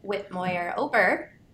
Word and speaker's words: Whitmoyer-Ober: Today we have a special guest with us, Ashton Whitmoyer-Ober.